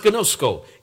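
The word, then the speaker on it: ginosko: Now that word, know him, is a Greek word pronounced gnosko.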